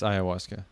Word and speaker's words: ayahuasca: I think it's just ayahuasca. – Yeah. – It's ayahuasca. – Yeah. – The H is silent.